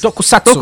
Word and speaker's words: Tokusatsu: O Jaspion Jason é o, provavelmente o personagem mais famoso aí dos, dos, dos seriados aí dos toku, toku, Tokusatsu, como é que fala isso? Tokusatsu.